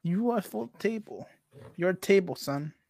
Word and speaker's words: Ufotable: Haiku? Not the haiku? – What the fuck is Ufotable? – Oh, you say Ufotable, bro. Not haiku.